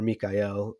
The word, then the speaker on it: Michael: So I also see Michael, or Mikael, if we want to go the Hebrew pronunciation way,